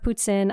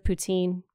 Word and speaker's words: poutine: And in my defense, I grew up in northeastern Ontario in Timmins, and I didn't have poutine. I know everyone else pronounces it poutine. You have to understand, northerner French, we say poutine.